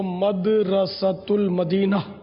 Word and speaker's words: مدرسۃ المدینہ: صحیح تلفظ جو ہے وہ مدرسۃ المدینہ جو انہوں نے بتایا وہی درست ہے.